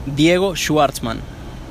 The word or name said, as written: Diego Schwartzman